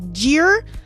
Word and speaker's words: gyr: Now, bred by the Jim Wilson Farm in Ontario, Canada, the juvenile three-quarter gyr, that's what Google told me it's pronounced as, even though it's spelled G-Y-R, it's like, but pronounced with a J, gyr,